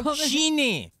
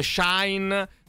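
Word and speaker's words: Shein: Allora, si karate, pronuncia Shin. – Shin. Come si pronunciava invece quell'altra? – Timiu. – Fastidio, adesso me lo impugno da sola. – Quindi non dite Shine, non dice Shine, non dice Shane.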